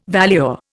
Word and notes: valuer: Indian

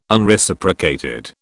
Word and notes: Unreciprocated: British English

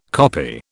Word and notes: copy: British English

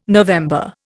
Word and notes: November: Indian

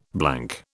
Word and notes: blank: British English

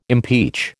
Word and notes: impeach: American English

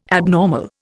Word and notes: abnormal: Indian

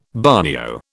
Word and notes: bagnio: British English

